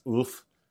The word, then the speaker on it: Ulf: I probably don't even pronounce it correctly. I think it's Ulf. – Right. – But I'm born and raised in Canada, so I got my way of pronouncing it.